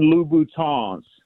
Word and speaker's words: Louboutin: And then most importantly, So the women that listen to the show don't start tagging you on YouTube, they are Christian Louboutins. You got to pronounce it with the French Louboutin.